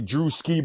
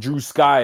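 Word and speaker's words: Drewski: I get it because people like the way that people say my name, it's supposed to be Drew Ski, but people put Drew Sky, and I'm like, that's not.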